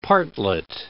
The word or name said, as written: partlet